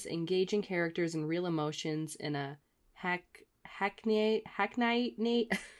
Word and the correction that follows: hackneyed: I cannot read this. How do you say this word? What word? – Hackneyed. – Hackneyed. Hack, hackneyed. Yes um, emotions in a hackneyed, hackneyed narrative arc.